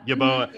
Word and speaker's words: Yeboah: Is it Kenny Yeboah, how you pronounce his name? – Yeboah.